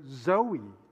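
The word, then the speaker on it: zoe (ζωή): Some people name their children Zoe. Zoe means life. It comes from the Greek word that's pronounced zoe.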